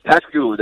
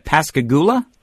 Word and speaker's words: Pascagoula: I was trying to figure out how to say the how do you say where you're coming from? Is that Pascagoula? – Yes, sir. Yes, sir.